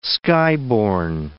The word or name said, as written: skyborne